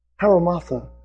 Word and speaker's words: hamartia: The Greek word for sin is a word, haramartha, is the way it's pronounced, as best I can tell. H-A-M-A-R-T-I-A.